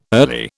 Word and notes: early: British English

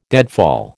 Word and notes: deadfall: American English

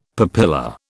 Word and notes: papilla: British English

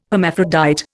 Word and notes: hermaphrodite: Indian

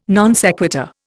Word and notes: non sequitur: Indian

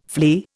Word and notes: flee: Indian

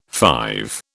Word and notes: five: British English

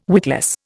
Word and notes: witless: Indian

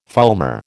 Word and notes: fulmar: American English

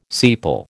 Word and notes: sepal: American English

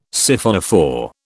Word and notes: Siphonophore: British English

